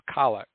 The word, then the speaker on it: collect: And it's spelled collect, so, but it's pronounced collect because of the Latin roots of, and continues with the readings, the homily, the creed, and the prayers of the faithful.